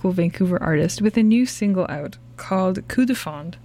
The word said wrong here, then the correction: coup de foudre: Did I say that right? – Coup de foudre. – So close. I don't know why I want to say frond.